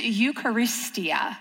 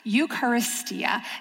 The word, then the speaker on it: Eucharistia: And the word that we're going to look at today in the Greek is Eucharistia. Eucharistia.